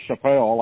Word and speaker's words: Chappelle: Beville was probably the old pronunciation, and also Chappelle, like Dave Chappelle, the mm-hmm.